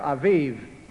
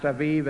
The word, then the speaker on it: Aviv: Before the Babylonian captivity, it was called the month of green ears, or Aviv. A-B-I-B, which was meant to be pronounced Aviv as in Tel Aviv.